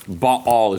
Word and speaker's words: Baal: Baal is the correct pronunciation.